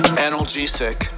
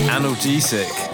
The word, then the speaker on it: analgesic: Analgesic. – Analgesic. Yeah, it's no anal.